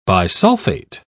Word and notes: bisulfate: British English

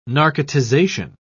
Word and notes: narcotization: British English

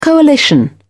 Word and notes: coalition: British English

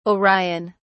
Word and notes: orion: British English

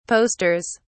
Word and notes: posters: British English

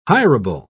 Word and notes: hirable: British English

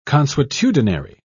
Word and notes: consuetudinary: British English

